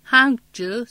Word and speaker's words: Hangzhou: Hangzhou, Suzhou, Wuxi diye okunur.